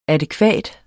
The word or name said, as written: adækvat